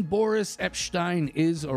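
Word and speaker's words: Epshteyn: and it's pronounced with the SH sound in the middle. It's not Boris Epstein or Epstein. It's Epstein.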